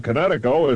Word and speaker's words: Kinetico: Oh, on the East Coast, son, you should have remembered kinetic as in kinetic energy with an O on the end. Connecticut. – Connecticut. Spell it K-I-N-E-T-I-C-O.